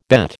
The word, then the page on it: bet: American English